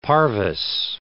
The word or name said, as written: parvise